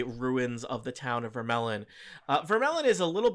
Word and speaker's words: Vermeillon: Is that how we agreed we're pronouncing it? Yep, it's sure. a melon. Vermelon. Vermelon. – Yeah. Vermelon.